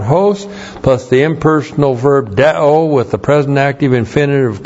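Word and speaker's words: deo (δέω): D-E long O, deo. Deo is called an impersonal verb.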